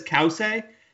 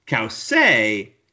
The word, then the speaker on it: Cauce: Okay, so she says Kause. That makes sense because on Wikipedia, her name is spelled C-A-U-C-E. So if I saw the I, I'm going chi at the end because I'm thinking it's almost Italian.